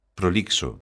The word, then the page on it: prolixo: galega